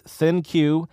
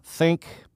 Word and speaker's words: ThinQ: The pronunciation of this smartphone is ThinQ, not Think, but, you know, whatever. Call it whatever you want.